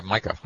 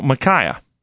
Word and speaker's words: Micaiah: Micah. Okay. – No, Micaiah. – Micaiah. Oh, it's spelled M-I-C-A-I-A-H, Micaiah.